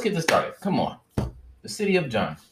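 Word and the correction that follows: Djenné: Djenne? Wait, let me make sure I said his name right. – Djenne. – Yeah, City of Djenne.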